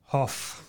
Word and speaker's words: Hough: His name is Richard Hough. – Hough. How do you spell that? – That's H-O-U-G-H.